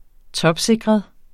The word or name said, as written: topsikret